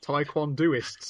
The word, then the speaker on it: taekwondoist: She's a taekwondist. Taekwondoist, sorry. I mispronounced my bad mispronunciation.